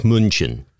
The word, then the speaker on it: München: I also wanted to apologize and say that you did, in fact, get very close to the actual pronunciation of Munich in German. Very, very close. – München? – It's more like München, but yeah.